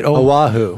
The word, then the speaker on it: Oahu: Oahu.